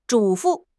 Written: 嘱咐

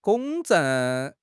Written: 公证

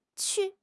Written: qu4

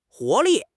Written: 活力

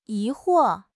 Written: 疑惑

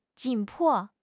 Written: 紧迫

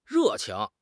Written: re4 qing2

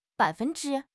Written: bai3 fen1 zhi1

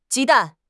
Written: ji1 dan4

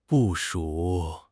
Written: 部署

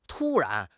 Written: tu1 ran2

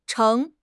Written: 乘

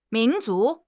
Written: min2 zu2

